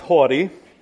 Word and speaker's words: haughty: And you guys let me say howdy, howdy doody. You know, I mean, like, come on. But anyway, now I know it's pronounced haughty.